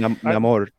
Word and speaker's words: Namor: Namor. It's Namor because it's like Niño sin Amor. – He has no love. – Oh, yeah. That's right. That's right. I forgot Namor.